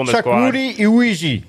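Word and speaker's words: Iwuji: It's weird to say his last name. It's so it's Uwe for for the last name. Like I said, it's very odd name to pronounce. Chakwu Uwe.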